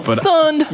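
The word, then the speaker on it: sonned: It's S-O-N-N-E-D. Sunned, I think. Like, yeah. – Yo, sunned. – Yeah.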